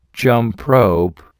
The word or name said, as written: jump rope